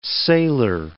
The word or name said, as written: sailer